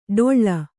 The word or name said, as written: ಡೊಳ್ಳ